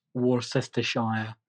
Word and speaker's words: Worcestershire: So this is how you correctly pronounce Worcestershire, Worcestershire.